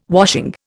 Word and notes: washing: Indian

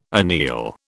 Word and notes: anneal: British English

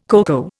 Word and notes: coco: Indian

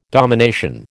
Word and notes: domination: American English

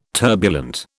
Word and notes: turbulent: British English

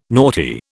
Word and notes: naughty: British English

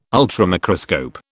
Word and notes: ultramicroscope: British English

